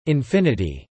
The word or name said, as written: infinity